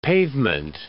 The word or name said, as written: pavement